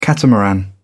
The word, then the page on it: catamaran: British English